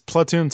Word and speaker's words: Splatoon: Splatoon. I mean, it's Splat. It's a platoon. Splatoon. I think it's Splatoon, so it's Splatoon.